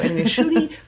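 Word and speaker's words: Ncuti: There is an N at the beginning of his name, but I did not want to be that white girl who didn't actually learn how to pronounce someone's name correctly. So Inushuni Gatwa.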